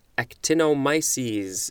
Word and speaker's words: actinomyces: We will start this episode with actinomyces. Is that the correct pronunciation? Actinomyces. Yep, that's it.